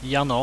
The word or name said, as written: jano